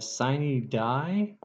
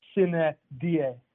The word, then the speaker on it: sine die: Like, if I was going to be, like, super pedantic Latin pronunciation, sine die. So I want the I and the E in sine to be short, and I want the I in die to be short, and the E in die to be long.